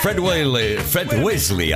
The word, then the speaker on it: Fred Wesley: Ya te lo digo yo que tiene algo que ver, porque Fred Wesley, ahora lo he dicho bien, and the JBs, es una banda que capitaneaba el mismísimo James Brown, que puedes escuchar, evidentemente, inconfundible.